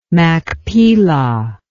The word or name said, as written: machpelah